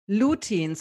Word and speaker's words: lutein: Lutein. So that's L-U-T-E-I-N, lutein.